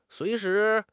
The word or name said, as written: sui2 shi2